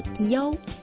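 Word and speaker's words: yao (幺): Outro detalhe interessante é que quando os chineses falam o número I, um em algarismo arábico, eles pronunciam yao ao invés de i.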